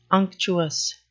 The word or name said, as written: unctuous